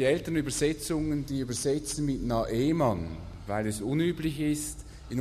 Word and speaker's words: Naaman: Und deshalb sind die neuen Übersetzungen, übersetzen dann mit Naaman.